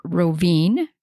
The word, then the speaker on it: Rovinj: And specifically, we are going to tour around a little town called Rovinj, which is spelled R-O-V-I-N-J.